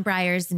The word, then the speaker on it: Briers: I'm Briars Newman. – B-R-I-E-R-S. – Yes, thank you. But pronounced like the ice cream.